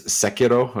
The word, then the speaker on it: Sekiro: So it's Sekiro. It would be how you say it if you want to be super specific, but yeah. – It's like the first vowel is supposed to be really hard in Japanese, right? – Mm-hmm.